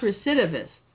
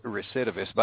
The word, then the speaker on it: recidivist: Yeah, I think that's pronounced recidivist. – Oh, okay. But, yeah, you're right, recidivist.